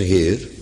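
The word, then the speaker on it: heir: In de praktijk is dat woord heer een tamelijk subtiel woord, want spreek je hetzelfde woord in het Engels uit, betekent het ineens erfgenaam. Engelse heer, H-E-I-R, R spreekt men dan uit, voor alle duidelijkheid.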